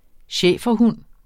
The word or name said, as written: schæferhund